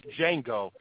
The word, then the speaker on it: Django: As opposed to just Django. He even says the D is silent, and folks still say the Django.